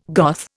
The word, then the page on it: Goth: Indian